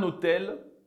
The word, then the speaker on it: hôtel: Enfin, il y a le H muet. Par exemple, un hôtel, des hôtels.